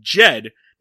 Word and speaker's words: Jed: I keep, I always try to like say Judd Clampett, but it's Jed because it very clearly rhymes with Fed in the theme song.